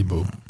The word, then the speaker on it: Bo: Les lettres sont plutôt collées, donc je dirais Bo. – C'est Bo. Comme Didley.